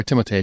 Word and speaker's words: Timothée: Now, do you feel, okay, so when you come to Paul Atreides, right, Timothy Chalamet, or Timothée Chalamet is actually how you say it, but everybody calls him Timothy.